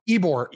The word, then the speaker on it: Ybor: Ybor. It's Ybor pronounced City? Ybor. Yeah. So when I said what? Ybor, I know it looks nothing like it's spelled.